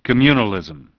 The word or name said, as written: Communalism